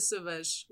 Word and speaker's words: Sauvages: It's La Tribu des Sauvages, which means the tribe of the wild. Yeah, the S. I don't pronounce the S at the end there. – But, yeah.